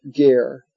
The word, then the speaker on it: Ger: There was a great rabbi, one of the Rebbeim of the Hasidic dynasty called Ger, the Ger Hasidim, or Gur, sometimes pronounced Gur Hasidim.